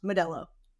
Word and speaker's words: Modelo: By the way, it's pronounced Modelo.